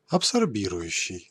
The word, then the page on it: absorbant: France; [ap.sɔʁ.bɑ̃]